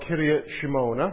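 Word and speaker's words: Kiryat Shmona: Kiryat Shemona.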